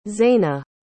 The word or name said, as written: zayna